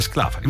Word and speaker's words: Sclafani: Sì, perché qui dice corretta è Palazzo Sclafani. – Allora Sclafani, vabbè, comunque perdonate l'ignoranza, meno male che c'è che mi sostiene con le pronunce.